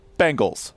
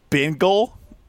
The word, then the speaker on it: Bengals: But the two mispronunciations that I absolutely love, Bengal is my favorite, because people just love saying the word, apparently just smack right into it. The other one is Bing, like, Bingle. Like, that's very much the, like... – Bingle. – Bingle. Yeah, like Boomer Esiason says it like that.